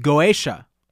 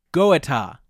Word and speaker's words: Goetia: you have been meddling with Goetia, I think is how you say it. Goetia, I think is not how you say it. G-O-E-T-I-A.